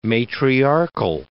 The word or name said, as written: matriarchal